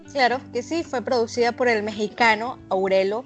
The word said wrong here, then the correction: Aureo: Te voy a decir cómo se pronuncia, Billy. Es Aureo Vaqueiro.